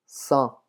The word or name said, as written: cent